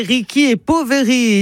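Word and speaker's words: Ricchi e Poveri: Si on prononce bien l'italienne, c'est Ricky et Poveri, ici de Romardèche, puisque ce groupe vient de Gênes, les riches et les pauvres.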